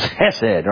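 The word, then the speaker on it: chesed: And you got to say it from the back of your throat, too. It's hesed, right? You got to spit at somebody when you say it. That's the way you rightfully pronounce it.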